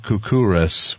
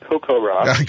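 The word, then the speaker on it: CoCoRaHS: When I say Kukuras, what does that mean to you? – Oh, so it's pronounced Kokoraz.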